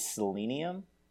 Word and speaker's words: Selenium: And so I think the proper pronunciation is Selenium. But I feel like I come across as a pretentious British person when I say Selenium. It's just like saying aluminium.